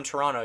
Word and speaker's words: Toronto: If you're from Toronto, you don't pronounce the second T.